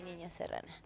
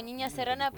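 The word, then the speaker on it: Ninha Serrana: Claro, me buscan como Niña Serrana, pero bueno, en Instagram se escribe Niña como en portugués, que es NINHA, como Nina-Serrana.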